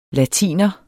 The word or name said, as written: latiner